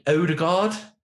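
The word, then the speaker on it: Ødegaard: And I mean Odegaard, is that how you say it? – Yeah.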